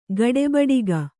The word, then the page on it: ಗಡೆಬಡಿಗ: gaḍebaḍiga